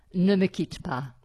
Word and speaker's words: Ne me quitte pas: Ne me quitte pas.